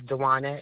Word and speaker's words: Duana: My first name is Duana. It's spelled D-U-A-N-A. My last name is Brown, B-R-O-W-N.